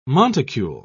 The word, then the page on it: monticule: British English